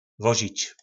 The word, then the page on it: vozic: po našimu